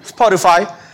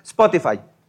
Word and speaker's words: Spotify: en esta plataforma de música de Spotify o Spotify, como quiera pronunciarlo, si quiere hacerle al, americano, Spotify, eh, si quiere pronunciarlo literal, como lo hacen en España o en algunas partes del mundo hispano, Spotify. Si quiere hacerlo más español, es Spotify. Este,